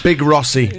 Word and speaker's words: Big Rossi: Can you say my name right, please? It's Big Rossi.